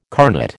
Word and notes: carnet: American English

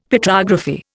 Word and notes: Petrography: Indian

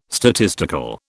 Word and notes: statistical: British English

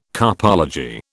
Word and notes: carpology: British English

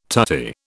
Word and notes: tutti: British English

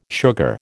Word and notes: sugar: American English

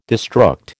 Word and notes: destruct: American English

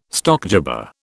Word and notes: stockjobber: British English

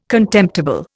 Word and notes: contemptible: Indian

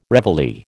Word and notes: reveille: American English

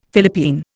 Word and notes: Philippine: Indian

